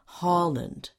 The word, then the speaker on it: Holland: Stressed on the first part of the word Holland, and the nationality is Dutch.